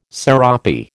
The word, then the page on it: serape: American English